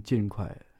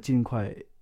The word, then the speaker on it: 尽快: Yeah, they're saying 尽快, fourth tone.